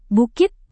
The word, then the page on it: bukit: Indonesian